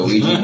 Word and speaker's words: Ouija: It's called O-U-I-G. – Oh, a Ouija board.